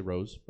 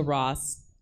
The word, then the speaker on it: Ross: Nellie Taylor Ross, I want to say Rose, but it's Ross.